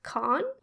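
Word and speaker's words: Cahun: It's spelled Cahoon, but I think it's pronounced Caen, or in a French way that I can't really grasp because I'm French.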